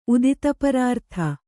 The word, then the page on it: ಉದಿತಪರಾರ್ಥ: kannada